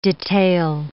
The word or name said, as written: detail